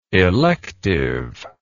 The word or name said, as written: electiveness